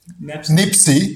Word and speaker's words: nepsis: Was it Nepsis? – Nipsi, yes, which is the Greek way of pronouncing, but it's N-E-P-S-I-S.